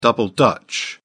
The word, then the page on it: double dutch: British English